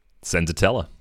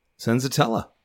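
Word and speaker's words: Senzatela: Senzatella, yeah. – Senzatella.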